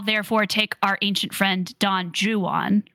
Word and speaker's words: Don Juan: That's actually the right way to say it if you if you are someone who's read Don well Don Juan, Don Juan, as a lot of people incorrectly say it by Lord Byron, because that poem is actually a satire, and so the whole point is is that like an English person would mispronounce that word that name.